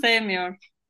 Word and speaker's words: Sevmiyor: is Tuna from Sevmiyor, right? Is that how you say it? – Sevmiyor, Sevmiyor. – Yes.